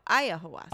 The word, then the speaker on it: ayahuasca: Ayahuasca. – No, ayahuasca.